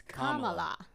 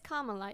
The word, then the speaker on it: Kamala: It's not Kamala, it's Kamala. – Yes. – So Miss Kamala is a Libra.